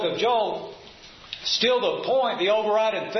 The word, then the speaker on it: Joel: And when you look at the word, we just say Joel, but if you have a self-pronouncing Bible, you'll see that it's divided up in at least two parts, Joel.